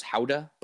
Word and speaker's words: Gouda: Especially when I went to the Netherlands, I went to to Gouda, the town, pronounced Houda, but that's where Gouda cheese is from, in a town named Gouda.